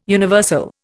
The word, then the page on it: universal: Indian